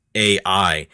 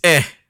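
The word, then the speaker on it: Æ: It does not sound like a really cool symbol because she thinks it's pronounced AI. Elon Musk thinks it's pronounced Ash, which if I think you're looking at another language or like a foreign language, that's true. But in English, it's pronounced ah. it's the A sound in cat. So it's eh.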